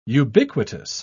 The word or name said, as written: ubiquitous